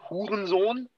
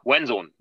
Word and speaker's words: Hurensohn: There's different ways. I mean, you can say Hurensohn. That would be like 100% proper German. Most people say it like actually it sounds like Chinese, Wen Zone, like something like that.